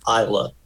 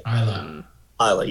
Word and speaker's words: Isla: It's pronounced Isla, by the way. – Isla, yeah.